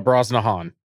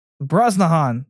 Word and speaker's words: Brosnahan: Rachel Brosnahan? – Uh, Brosnahan. – Brosnahan.